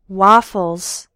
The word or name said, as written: waffles